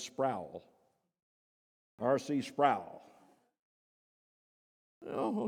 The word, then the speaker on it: Sproul: It's Sproul, rhymes with soul.